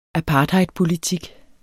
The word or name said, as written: APARTHEIDPOLITIK